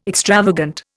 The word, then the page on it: extravagant: Indian